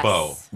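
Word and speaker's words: Beaux: Bow. – B-E-A-U. – Okay. Mm-hmm. No, but it's B-E-A-U-X apostrophe on the, on the thing.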